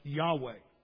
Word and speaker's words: Yahweh: It is spelled in the Hebrew Y-H-W-H, pronounced Yahweh.